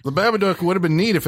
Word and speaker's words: Babadook: Babadook. – Babadook. – Duck rhymes with book.